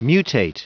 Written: mutate

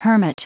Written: hermit

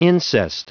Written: incest

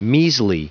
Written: measly

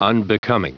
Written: unbecoming

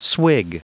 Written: swig

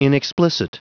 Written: inexplicit

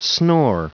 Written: snore

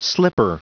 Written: slipper

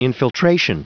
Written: infiltration

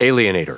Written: alienator

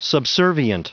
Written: subservient

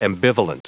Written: ambivalent

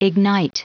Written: ignite

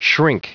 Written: shrink

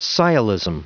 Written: sciolism

